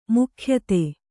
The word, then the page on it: ಮುಖ್ಯತೆ: mukhyate